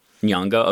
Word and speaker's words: Nyong'o: And, Lupita, I can't say her name very well. – I think I've heard it as Nyong'o. – Nyong'o, okay.